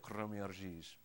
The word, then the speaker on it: Kroměříž: I just think of crummy cheese. That's close to how you say it, Kroměříž.